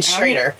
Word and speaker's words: Schrader: And next we have Jack Schroyer and Associates. Did I say that right? – You did. Schrader. – Schrader. – Oh, Schrader. – It is. Uh-huh. Yeah, that'll throw you off in the spelling.